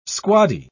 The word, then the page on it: squaddy: British English